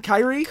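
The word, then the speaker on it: Kirie: Because I think Japanese is almost always pronounced phonetically, like the way you'd read it. So if it's if it's Kyrie, it's Kyrie.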